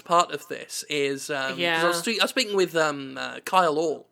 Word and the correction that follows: Kyle Orland: totally forgot how to say his name. Sorry, it's early in the morning. Kyle Orland, sorry. I don't know why I called him Kyle Orl.